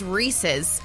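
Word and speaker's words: Reese's: That's not how you say that. That is not how you say it. It is Rees-is.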